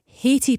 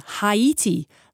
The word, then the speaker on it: Haiti: Brian in Langside, not a name mispronunciation that is irritating me, but as it's ongoing, Haiti pundits and others are saying Haiti, like Tahiti, but it should be Haiti. Come on, Scotland. This is anonymous. Yes. M-E-N-Z-I-E-S is Mingus, not Menzies.